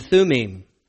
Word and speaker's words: Thummim: or the I don't even know how other people pronounce it, but Hebrew pronunciation would be Urim and Thummim.